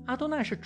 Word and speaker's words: 阿多奈: 这难不倒律法主义者们，他们让以色列人每一次看到亚会都读成阿多奈。阿多奈是主的意思，可以用来称呼一个国王，一个奴隶主，一家之主，甚至是一个牧童，因为他也算是羊群的主。